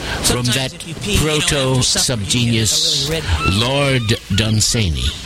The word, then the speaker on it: Dunsany: which is incidentally how you pronounce his name. This member of the aristocracy, some pronounce it Lord Dunsany. I'm here to tell you that is quite wrong. His name rhymes with Cheney, with Dick Cheney, Lon Cheney, Lon Cheney Jr., Liz Cheney, and other strange creatures.